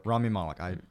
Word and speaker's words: Malek: How do you say his name? Malek. – Rami Malek.